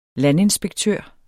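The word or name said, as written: landinspektør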